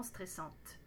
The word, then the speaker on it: stressant: N'oubliez pas, après C, on utilise un adjectif masculin singulier, donc dites c'est stressant. It's stressful. Ne prononcez pas le T.